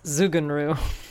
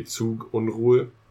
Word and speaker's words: Zugunruhe: So Z's in German are pronounced with a T sound, like a Tsetse fly has the same sound for the TS. Mm-hmm. T. So it'd be Zugunruhe.